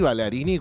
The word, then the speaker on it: Ballarini: Me gusta que la ciencia esté en una y esté con nosotros Fabrizio Ballarini, Ballarini, como ustedes decían pronunciarlo, a él no le molesta.